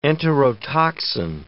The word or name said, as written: enterotoxin